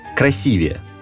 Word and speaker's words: красивее: Ударение на предпоследнее «е» ставится лишь в прилагательных, состоящих из двух слогов, те самые «нежнее» и «умнее». В остальных случаях ударение в сравнительных степенях сохраняется. «Красивый» – «красивее», «прекрасный» – «прекраснее».